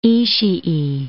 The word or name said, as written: Ishii